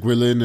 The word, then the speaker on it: Guilin: And actually nearby-ish, I mean, I went to visit Guilin and Yangshuo.